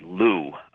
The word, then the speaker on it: Lugh: Going back to a god, I mentioned a Celtic god named Lu. It's pronounced that way. It's spelled L-U-G-H.